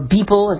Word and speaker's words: Beeple: There was the artwork by the digital artist People, or Beeple is how you say it really, that sold for $69 million at Christie's, and that got attention.